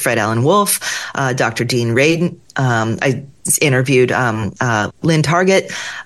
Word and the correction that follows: McTaggart: McTaggart. Yes, McTaggart. Yeah. It's still early here in San Diego. Target, nope. McTaggart.